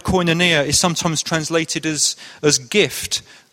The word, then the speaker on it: koinonia: I'm quite sure that's how you pronounce it, but that's what I'm going to go with, koinonia.